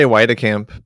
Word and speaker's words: Weitekamp: Over how here, I say Weitekamp, but I think there's some Dutch Weitekamps and some German Weitekamps.